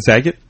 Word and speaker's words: Zagat: Is that how you say it? Zagat? – Zagat.